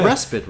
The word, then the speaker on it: respite: Some people say respite, right? So is it respite, respite? I said you could pronounce it both ways, and both ways are equally acceptable.